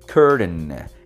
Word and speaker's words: curtain: Esa pronunciación es muy americana. La T es muy suave y se va a pronunciar curtain. Es una D muy suave en la pronunciación, ¿ok?